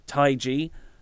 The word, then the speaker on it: Taiji: which was Takahashi versus Taiji. Said that right, didn't I? – Yes.